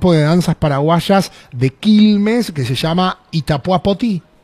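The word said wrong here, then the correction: Poty: Potu. – ¿Itapuá? – Potu. – Potu. – Muy bien. – Ahí está, no se dice la I. – No. – Ahí está. La I se dice U.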